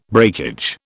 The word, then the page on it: breakage: British English